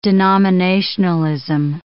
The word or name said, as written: denominationalism